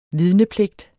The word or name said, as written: vidnepligt